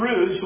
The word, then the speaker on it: Rudd: Farrell Jenkins and this man, Steve Rude, the way I've always pronounced it, R-U-D-D, those two men, you need to go to their website.